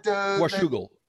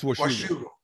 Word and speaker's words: Washougal: How do you pronounce that? – Washougal. It's Washougal.